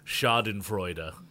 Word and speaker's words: Schadenfreude: Yeah, and that's actually the actual, if I get close to the pronunciation, it's Schadenfreude, and that's when you take joy in the misery of others, or the misfortune of others, yeah.